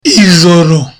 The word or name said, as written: ijoro